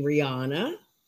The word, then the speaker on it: Riana: Just so you know, you pronounce my name Rihanna. – Gotcha. – Milne, M-I-L-N.